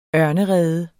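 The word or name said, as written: ØRNEREDEN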